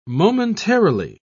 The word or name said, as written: momentarily